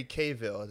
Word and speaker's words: Cavill: And, you know, Henry Cavill, that's how you say his name.